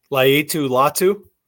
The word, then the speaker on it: Laiatu Latu: Laiatu Latu.